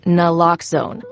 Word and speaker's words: naloxone: For the record, it's Naloxone. Nuh, like, is Naloxone addictive? Nuh. Lox, like Naloxone keeps humans safe, like locks keep bikes safe.